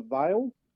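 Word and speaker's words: Veil: One's called the Vale Brewing. So that's like it's spelt like a V-E-I-L.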